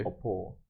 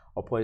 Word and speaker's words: Okpor: Okpor, and not Okpor cool is my name. last name. Okpor is spelled as O K P O R, but the K is silent.